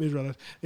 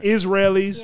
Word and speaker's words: Israelis: I was about to call them Israelites. This ain't Bible times. We don't say it like that no more. Okay. Israelis.